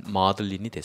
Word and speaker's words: maudlin: 그래서 막달리나 마리아에서 왔는데 이 막달리나를 영어식으로 발음하다 보면은 막달리나, 막달리나, 마들린, 마들린, 마들린이 됐어요.